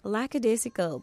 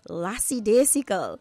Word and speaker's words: lackadaisical: and she used the word lackadaisical, but her pronunciation was lassidaisical.